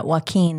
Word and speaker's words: Joaquin: Yeah, Joaquin. – Am I saying that right? – Yeah, Joaquin.